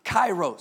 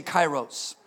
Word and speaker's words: kairos: This is the word kairos. Somebody say kairos. It's spelled K-A-R-I-O-S, kairos.